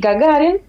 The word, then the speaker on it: Gagarin: Sí, sí, bueno, se pronuncia así, Yuri Gagarin. El acento cae en la penúltima sílaba.